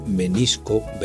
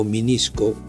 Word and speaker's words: menisco: Siguiendo este fenómeno, todo quisque pronuncia todo quisqui, o muchos articulan menisco, vesícula o derretir como minisco, visícula y derritir, incluso dirritir.